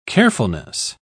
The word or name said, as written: carefulness